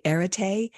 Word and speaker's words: Arete: am I saying this correctly? Arete, kind of. – That's correct.